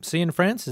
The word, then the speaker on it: Cianfrance: I've been watching the new Derek Cianfrance, is that how you pronounce his name? – Yeah.